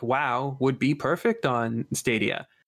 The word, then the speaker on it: Stadia: Yeah, I think it's Stadia. It's like Stadium, but yeah.